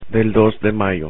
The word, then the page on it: del dos de mayo: spanish